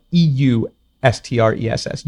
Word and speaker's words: eustress: So eustress is spelled E U S T R E S S, eustress.